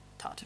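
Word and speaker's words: Toht: He's called Todd in it, but it's not written like that. It's T-O-H-T. – Okay. – But it's pronounced Todd.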